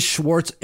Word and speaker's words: Schwartz: It is Schwartz.